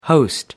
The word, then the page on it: host: American English